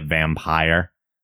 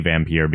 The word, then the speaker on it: Vampyr: Some people are calling it Vampire. But I guess in more olden times, that traditionally had an E at the end to call it Vampire. – Yeah. – So people are pronouncing it both ways. I say Vampyr because it also indicates what I'm actually talking about.